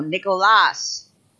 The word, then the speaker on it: Nicolas: I brought this up because Brian Keating was on that show, and it reminded me of Pedro Martinez saying, this is how you say Nicolas Castellano, Nicolas. That's how.